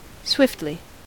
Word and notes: swiftly: American English

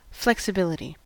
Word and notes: flexibility: American English

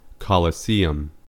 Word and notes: colosseum: American English

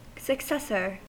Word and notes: successor: American English

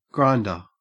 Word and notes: grinder: Australian English

